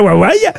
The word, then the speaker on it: Huawei: Comment on prononce? – Huawei. – Huawei. – Huawei. – Ah ben voyez. – Huawei. – Qui c'est qui avait raison? C'est bien Huawei.